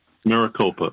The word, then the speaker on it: Maricopa: Maricopa, right.